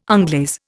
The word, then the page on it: onglaze: Indian